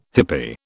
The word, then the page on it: hippie: British English